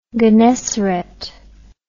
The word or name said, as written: gennesaret